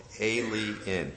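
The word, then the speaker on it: A'LE INN: And they opened a restaurant there called, it's spelled like this, A apostrophe L-E, capital I-N-N. It's an inn where people can eat and drink. It's called Alien. L-E-N.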